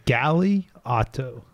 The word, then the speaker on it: Galeotto: Galeotto. – Yeah, see, I would have definitely said that wrong. – It's like galley like the boat, galley like a boat, auto like a car. You got it.